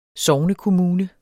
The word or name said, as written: sognekommune